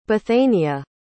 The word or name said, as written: Bethania